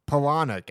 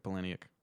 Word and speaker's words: Palahniuk: Hell yeah, Palahniuk actually is how you say it. – Chuck Palahniuk.